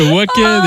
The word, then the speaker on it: Wacken: Eh oui, le Wacken, évidemment.